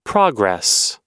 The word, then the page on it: progress: American English